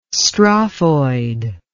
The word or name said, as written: strophoid